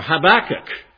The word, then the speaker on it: Habakkuk: Now would you turn with me to the book of, I hope I'm pronouncing it correctly. It may be correct in Oregon, but it may not be correct in Michigan. Habakkuk. Or Habakkuk, however you want to pronounce it.